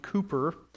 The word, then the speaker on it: Cowper: It's spelled Cowper, but it's pronounced Cooper.